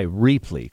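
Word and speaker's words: Rheaply: Reapply, it looks like, I like the way I pronounced it, Reapply, because it's sort of repurposing things, but it's R-H-E-A-P-L-Y, Reapply, correct? – That's right.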